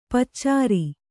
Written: ಪಚ್ಚೇರಿ

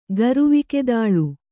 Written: ಗರುವಿಕೆದಾಳು

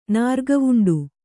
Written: ನಾರ್ಗಾವುಂಡ

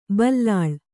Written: ಬಿಲ್ಲಾಳ್

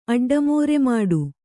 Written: ಅಡ್ಡಮೋರೆಮಾಡು

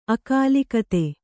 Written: ಅಕಾಲಿಕತೆ

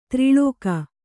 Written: ದೂರಲೇಖ

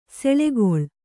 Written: ಸುಳಿಗೊಳ್

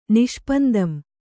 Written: ನಿಷ್ಪಂದಂ

